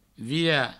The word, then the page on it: virar: vira